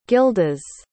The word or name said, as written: gildas